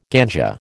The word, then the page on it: ganja: American English